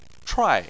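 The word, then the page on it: chuai4: tone 4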